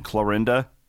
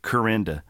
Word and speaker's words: Clarinda: The one thing, if you go back to my hometown, so where we live in Clarinda, well, like, I would say 70% of the town calls it Corinda.